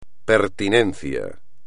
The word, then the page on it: pertinencia: espagnol